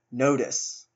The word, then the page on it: nodus: American English